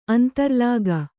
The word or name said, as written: ಅಂತರ್ಲಾಗ